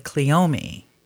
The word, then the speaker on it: cleome: Some people say cleome. Mm-hmm. There's an accent on the E. So I've always said cleome.